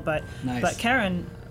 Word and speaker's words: Charon: The moon Charon, it was named in part after the discoverer's wife, whose name was Charlene. So the community pronounces it Charon after Charlene, but it's officially named after a mythological figure. So and the correct way to pronounce it in that case is Charon, but in a scientific conference you're not going to hear that pronunciation.